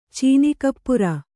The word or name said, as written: ಚೀನಿಕಪ್ಪುರ